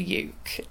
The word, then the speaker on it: uke: I would refer to it as a uke because it rhymes with puke.